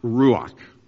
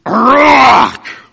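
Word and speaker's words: ruach (רוח): It is pronounced ruach. Let me tell you what it sounds like in Hebrew. Prepare yourselves here. It's pronounced ruach. Ruach. Very guttural. Full lunged.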